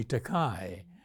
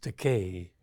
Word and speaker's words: Takei: Many people mispronounce my surname. They call me Takei. So this is my opportunity to correct them. Mm-hmm. It's pronounced Takei.